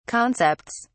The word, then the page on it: concepts: British English